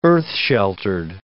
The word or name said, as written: earth-sheltered